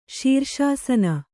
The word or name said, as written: ಶೀರ್ಷಾಸನ